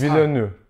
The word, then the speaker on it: Villeneuve: Villeneuve müydü? – Denis Villeneuve. – Aa bak, baş harflerini yerini değiştirerek yanlış söyledim. Böyle bir yanlış söyleme olabilir mi? – Estağfurullah. – Denis Villeneuve.